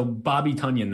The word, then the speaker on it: Tonyan: Chat's making fun of your Robert Tonyan pronunciation. – Oh, is it Tonyan? Oh, come on. These guys in the chat just found out the real way to say it like 3 days ago. – You're a Packers fan, dude. – Oh, yeah, Robert Tonyan. All right, so Bobby Tonyan then. Is that the proper way to say it?